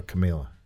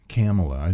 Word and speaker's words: Kamala: Is it Kamala or Camila? – Kamala. It's pronounced like Pamela.